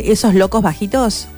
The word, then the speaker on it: Esos locos bajitos: Esos locos bajitos.